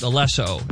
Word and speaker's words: Alesso: It's actually pronounced Alesso, and he's just the guy who makes the beats.